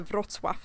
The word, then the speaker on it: Wrocław: Se om jag kan uttala det rätt nu, men det står att man ska eh, uttala det Wrocław som är det är också känt som, som Breslau på tyska.